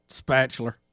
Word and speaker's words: spatula: No, how does he say it? – Spatula. – Spatula. Spatula. – Like rhymes with bachelor.